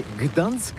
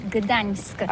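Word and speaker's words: Gdańsk: Ça s'écrit G-D-A-N-S-K. On dit Gdansk ou Dansk, on prononce le G ou pas?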